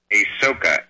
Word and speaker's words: Asoka: How do you pronounce that? King what? – Asoka. A-S-O-K-A.